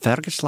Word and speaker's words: Fergus: You've got to pronounce it Fergus, lassie.